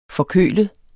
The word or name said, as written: forkølet